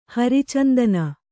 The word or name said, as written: ಹರಿಚಂದನ